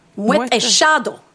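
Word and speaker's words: Moët et Chandon: Men det uttalas Moët en Chandon. – Moët en Chandon.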